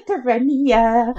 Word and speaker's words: Terenia: I also like the fact that it rhymes with millennia. So you can say Terenia, like what a millennia.